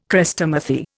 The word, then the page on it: Chrestomathy: Indian